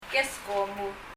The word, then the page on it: keskomu: [kɛskɔm]